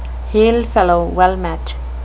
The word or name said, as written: hail-fellow-well-met